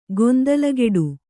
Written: ಗೊಂದಲಗೆಡು

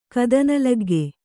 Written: ಕದನಲಗ್ಗೆ

